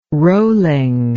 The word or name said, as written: rolling